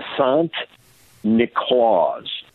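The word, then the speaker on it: Sint Niklaas: And then you have, now St. Nicholas in Dutch is pronounced Sant Niklaus.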